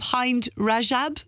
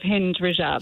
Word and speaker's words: Hind Rajab: Is it The Voice of Hind Rajab? Is that how you say it? – No. You're nearly right. It's The Voice of Hind Rajab.